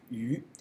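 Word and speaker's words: yu (鱼): Because the fish in Chinese is yu, mm-hmm. – is the same pronunciation of to the surplus.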